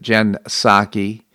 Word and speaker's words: Psaki: So the White House spokesman Jen Psaki because it's spelled P-S-A-K-I. I think she pronounces it Jen Psaki, irrespective.